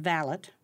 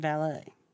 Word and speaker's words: valet: It breaks down to the English pronunciation valet versus the French pronunciation valet.